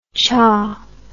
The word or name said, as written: cha